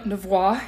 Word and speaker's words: Nouveau: We are not French. We're from Oklahoma. so Nouveau, if we want to say it correctly. But it's Nouveau.